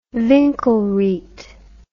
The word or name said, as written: winkelried